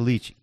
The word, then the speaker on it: lychee: Your wife said he had a lychee, which is, you know, at least say it correctly. – It's a lychee.